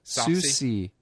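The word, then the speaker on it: Soucie: Nope, doesn't have an R in it. – Yeah, Susie. Spelled like it sounds.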